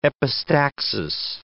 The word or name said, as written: epistaxis